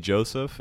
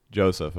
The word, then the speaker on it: Josef: So yeah, you can find me on Instagram or Facebook the one and only Joseph, and that's spelled the number one and only Joseph, and my name is J-O-S-E-F.